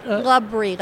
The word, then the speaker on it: Labrie: Labrie, like the cheese. – Labrie, L-A-B-R-I-E? – Labrie.